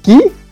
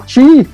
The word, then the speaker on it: Chi: Shang, se pronuncia Ki. Siempre decía Chi.